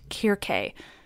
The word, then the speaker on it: Circe: Now I just want to pause at the mention of her, Circe, to tell you that technically, Circe should be pronounced Kirke, since C's were always hard. But I won't do that because I pronounce lots of C's soft because I'm English,